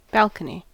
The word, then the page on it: balcony: American English